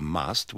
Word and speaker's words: must: In these sentences, remember to pronounce the U of must with the schwa and the U of mustn't with the sound of the U in cut.